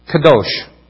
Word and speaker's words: kadosh: The Hebrew word for holy is spelled out Kof Delif Shin, often pronounced Kadosh, which is said about what that word means.